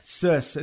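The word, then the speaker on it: Circe: So the villains in that are apparently going to be Cersei? – Yes. – Cersei. It's C-I-R-C-E. – Let's go with your pronunciation then.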